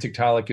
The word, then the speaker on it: Tiktaalik: And Tiktaalik is spelled T-I-K-T-A-A-L-I-K, Tiktaalik Rosea.